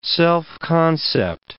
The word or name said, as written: self-concept